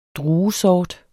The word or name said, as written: druesort